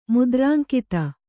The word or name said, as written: ಮುದ್ರಾಂಕಿತ